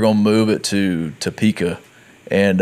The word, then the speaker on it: Topeka: And so that's how you say it, Topeka.